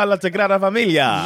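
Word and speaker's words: Sagrada Família: Ah, La Sagrada Familia.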